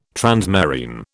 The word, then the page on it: transmarine: British English